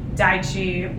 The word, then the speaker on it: Deqi: So they referred me to Daichi, which is like um, it's spelled D E Q I, and Lita to Lita and Daniel.